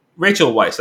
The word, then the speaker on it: Rachel Weisz: And I don't know, I also like Rachel Weisz, that's how you say her name.